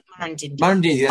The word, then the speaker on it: Murrindindi: Murrindindi, if I'm saying that right. – Murrindindi, that's right.